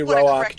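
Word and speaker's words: Roäc: So the A over the A in Roach has the two dots. – Yes. Yes. So that means it is definitively Roach, two syllables.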